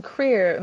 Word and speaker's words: CRER: And Creer, am I pronouncing it right? C-R-E-R. – Yeah. – Creer's work and C-R-E-R.